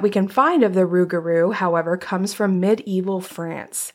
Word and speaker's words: rougarou: A Rougarou. – Yeah, I looked up the pronunciation, so I'm like 97% sure that that's correct.